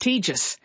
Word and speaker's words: tedious: I don't know, a word like tedious, that we say tedious now, that was once pronounced tejus. And that was the recommended pronunciation.